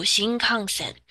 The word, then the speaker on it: 新幹線: 新幹線です。那大家应该还蛮熟悉这个词语的啦。说到日本，说到那个旅游的话，都会想到新干线这个东西。那中文知道怎么念，日文也要知道一下。新干线三个字读新幹線。